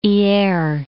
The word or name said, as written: Hyeres